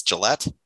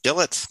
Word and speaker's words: Gillette: I, I, do you think I pronounced that right? It's Gillette. I will go with Gillette. Uh, Gillette. Yes, we'll say G I L L E T T, but it could be Gillette.